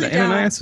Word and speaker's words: Ananias: That was really good. Ananias was pretty close. – Ananias. Well, we went did I say it right? – Yeah, you did really well.